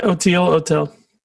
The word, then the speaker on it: Oteil: And Otel, am I saying it right? Otel? – Yeah, that's right. Otel.